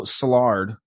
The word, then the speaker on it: Szilard: And this Leo Szilard, if I'm pronouncing his name right, it's S-Z-I-L-A-R-D. Szilard is how I'm saying it, and I'm sticking to it.